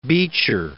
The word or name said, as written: Beecher